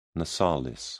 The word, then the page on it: nasalis: British English